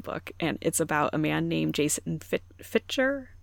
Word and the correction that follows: Fitger: I assume that's how you pronounce it. It's Fitger.